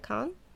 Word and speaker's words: Cannes: Cannes? – Yeah.